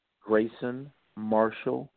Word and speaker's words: Grayson Marshall: G-R-A-Y-S-O-N. Marshall, M-A-R-S-H-A-L-L, Jr., J-R, .com. Grayson Marshall, Jr. So Grayson Marshall, J-R, .com.